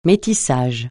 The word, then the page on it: métissage: francais